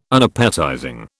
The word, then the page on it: Unappetizing: British English